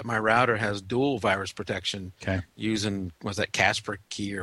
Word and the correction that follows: Kaspersky: or whatever? I could never say that right. – Yeah. – And then another one. – Kaspersky. – Yeah.